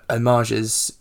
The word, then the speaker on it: homage: But obviously, because there are so many homages in... Is that how you say it? Homage? – Yeah, or references.